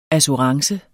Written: ASSURANCEN

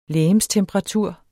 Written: legemstemperatur

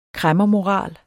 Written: kræmmermoral

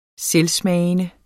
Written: selvsmagende

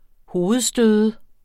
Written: hovedstøde

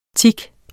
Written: tic

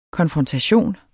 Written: konfrontation

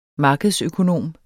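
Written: markedsøkonom

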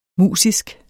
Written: musisk

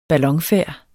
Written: BALLON

